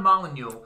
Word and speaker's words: Molyneux: I was going to guess Stefan Molyneux. – That's how you say his name? – Yeah, it's Stefan Molyneux.